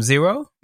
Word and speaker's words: Xiro: But you then have this finished album, you called it Zero? – Yeah. – Huh, so like Ground Zero, I guess, huh? – Beginning. But it's spelled with an X. It's spelled X-I-R-O.